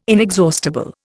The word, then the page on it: inexhaustible: Indian